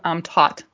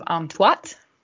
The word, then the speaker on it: Am-Tuat: He says Natu is from the Book of Amtat, which it's actually the Book of Amtuat. Like you, he says it as like A-U, but it's U-A in that second syllable, which is kind of a how-to guide on passage through the ancient Egyptian otherworld regions.